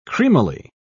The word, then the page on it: creamily: British English